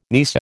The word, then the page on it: nisei: American English